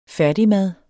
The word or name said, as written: færdigmad